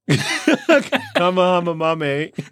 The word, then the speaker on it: Kamehameha: You just Kamehamehame's it. – Kamehamehame. Okay, the release of the new Dragon Ball movie, we heard a lot of mispronunciations this week. – Kamehameha. – Almost, dude.